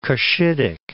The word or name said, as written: Cushitic